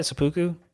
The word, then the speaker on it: seppuku: But, yeah, so she gets seppuku'd, and is that how you say it, seppuku? – So I say seppuku, yeah. We'll just go with that.